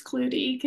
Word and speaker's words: Klute: My maiden name is spelled K-L-U-T-E. How would you pronounce that? – Clut? Clute? – A lot of people say clue. It's Clutie because you say the E as well.